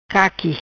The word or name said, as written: Caqui